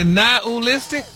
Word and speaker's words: nihilistic: But you actually pronounced it correctly the last time you said really? – It. – Really? Nihilistic? – Wow. – Stop putting the ooh in there. – Nihilistic? – There you go.